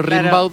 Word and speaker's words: Rimbaud: Como se escribe, se pronuncia Rimbaud, pero Rimbaud.